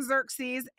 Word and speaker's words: Xerxes: It doesn't look like it's spelled like that, but if you look it up in the original language, that's the way that it's supposed to be sound, apparently. Xerxes, who reigned from India to Ethiopia over 127 provinces.